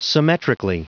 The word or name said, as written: symmetrically